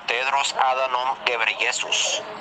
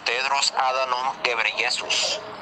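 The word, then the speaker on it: Tedros Adhanom Ghebreyesus: Se pronuncia Tedros Adanom Ghebreyesus. – Otra vez. – Se pronuncia Tedros Adanom Ghebreyesus.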